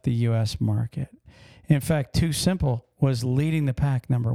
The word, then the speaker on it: TuSimple: Too Simple, which is spelled T-U-S-I-M-P-L-E.